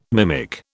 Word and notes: mimic: British English

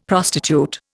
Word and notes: prostitute: Indian

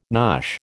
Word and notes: nosh: American English

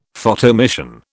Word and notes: Photoemission: British English

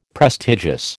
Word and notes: prestigious: American English

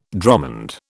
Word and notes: dromond: British English

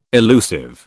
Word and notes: elusive: British English

